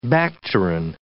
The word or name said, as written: bacterin